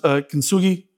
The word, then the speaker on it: kintsugi: Now, I'm probably going to butcher this Japanese word, but I believe it is pronounced kintsugi, and it means joining with gold.